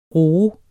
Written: roe

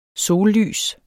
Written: sollys

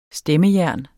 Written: stemmejern

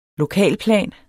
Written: lokalplan

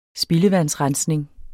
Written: spildevandsrensning

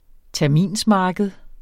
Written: terminsmarked

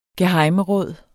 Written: gehejmeråd

